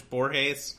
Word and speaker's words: Borges: We call them Borges, or but I'm pretty sure it's Borges. Borges.